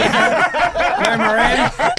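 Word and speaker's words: Lourmarin: So that's a place, Loumarin. – Loumarin, where we played Patong. – No, you're right about the pronunciation. – Loumarin. – That was bang on.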